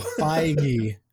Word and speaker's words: Feige: The film, directed by John Glean, features interviews with Disney Executive Chairman Bob Iger, Marvel Studios Kevin, is it Feige? Did I say that right? – No. You might get in trouble with that pronunciation. – So. I do not think so. – Feige. – Feige. Well, then yeah. It should be F-I-E-G-E, not F-E-I-G-E.